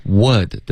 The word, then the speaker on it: would: Would 的这个的音一定要发出来，千万不要把 would 这个字呢发成 u。 OK，这个呜的发音是会导致误解的。